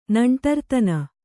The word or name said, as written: ನಂಟರ್ತನ